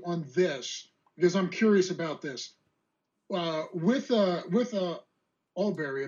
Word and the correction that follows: Arbery: if I'm saying the name right, I hope I am. – Arbery. Aubrey.